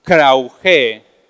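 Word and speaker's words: Krauge (κραυγή): Es una palabra en griego que se pronuncia de la siguiente manera, Krauge, Y krauge significa palabras que causan dolor.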